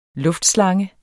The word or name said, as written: luftslange